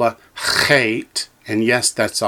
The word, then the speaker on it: chet: It's a chet, like C-H-E-T, but with a rasping ch sound, like in the word Bach, the German composer, Johann Sebastian Bach.